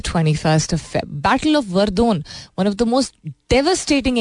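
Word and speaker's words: Verdun: वर्दोन था क्या वर्डन लिखते हैं इसको वर्दोन I think would be द सही प्रोनाउंसिएशन 21 फरवरी को um,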